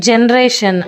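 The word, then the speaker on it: generation: ಅದೇ ರೀತಿ ಜನ್ರೇಷನ್ ಜೆಗೆ ಉಚ್ಚಾರ ಬರಬೇಕೇನೋ ಅನಿಸುತ್ತೆ, ಆದರೆ G E N E R A T I O N ಜನರೇಷನ್ನು, ಇಲ್ಲಿ ಜಿ ಬಂದಿದೆ. ಇನ್ನು ವಗೆ ವಿ ಮತ್ತು ಡಬ್ಲ್ಯೂ ಲೆಟ್ರನ್ನ ಬಳಸ್ತಾರೆ.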